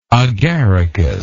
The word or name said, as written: agaricus